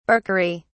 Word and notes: barkery: British English

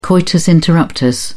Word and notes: coitus interruptus: British English

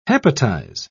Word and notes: hepatize: British English

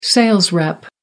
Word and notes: sales rep: American English

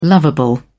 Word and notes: lovable: British English

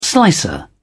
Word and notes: slicer: British English

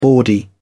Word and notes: bawdy: British English

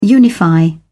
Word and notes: unify: British English

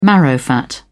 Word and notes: marrowfat pea: British English

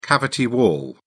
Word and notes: cavity wall: British English

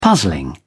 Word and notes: puzzling: British English

